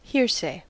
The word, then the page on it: hearsay: American English